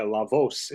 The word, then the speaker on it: La Voz: La Voz, if I'm saying that correctly.